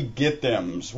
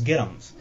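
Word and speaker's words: get 'ems: Being from the south and it's actually get ums. Yeah, it, it, it, it's pronounced. The correct way to pronounce it is get ums.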